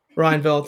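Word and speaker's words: Rijneveld: The 2020 International Booker Prize winner, Marieke Lukas Rynekveld. I hope I'm pronouncing that last name correctly. – Rynekveld, thank you.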